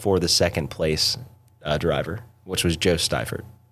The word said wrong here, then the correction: Seifert: or Joe Seifert. That's how you say it.